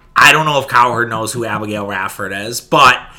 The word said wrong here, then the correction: Ratchford: Ratchford, I guess, would be the proper way to pronounce it.